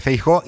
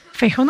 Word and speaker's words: Feijóo: Feijóo no lleva tilde porque es una palabra llana y acaba en vocal. Esto se separa Feijóo. O sea, esas dos vocales iguales son siempre un hiato.